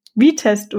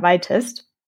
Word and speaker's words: Vitest: Aber ich war selber letzt vor kurzem bei der Viewtails Amsterdam und habe auch dort bei jedem Talk eine andere Aussprache gehört. Wir sprechen auf jeden Fall von V-Test oder Y-Test.